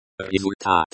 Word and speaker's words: результат: You will hear the te at the end of результат. Only slightly, and it is pronounced more like a d sound.